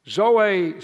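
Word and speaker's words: zoe (ζωή): This word life, the Greek word is zoe, spelled Z-O-E, but pronounced Z-O-A, zoe.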